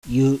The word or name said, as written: YU